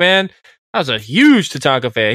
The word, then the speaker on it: Tatanka: Who was better, Razor Ramon, Doink the Clown, or Tanaka? Am I saying that right? – Yeah. – Tatanka.